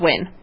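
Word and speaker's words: Nguyen: It's pronounced Win. – Win? – Oh, yeah, Amanda Win. Yeah. Mm-hmm. – It is? – Yeah. – Yes. That's why I didn't get the first name there. But yes, she's correct. Win. – It's, how do they get Win out of N-G-U-Y-E-N, I wonder? Win.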